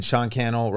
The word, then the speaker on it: Cannell: And I mean, Sean Cannell rhymes with YouTube channel, SeanCannell.